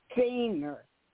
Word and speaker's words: Senir: I guess I'm pronouncing it right. Senir, S-E-N-I-R. – Yep, that is correct.